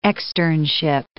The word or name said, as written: externship